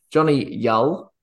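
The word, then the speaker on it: Jonny Yull: Johnny Yull, which is now the proper pronunciation per the commentators in this game, it's Johnny Yull.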